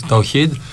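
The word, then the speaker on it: Ταουχίντ (توحيد): Αυτή, δεν ξέρω αν το προφέρω και σωστά, λέγεται Ταχίντ. Έτσι δεν είναι? Εντάξει, έχει ένα WKK. – Ταουχίντ.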